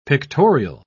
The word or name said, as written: pictorial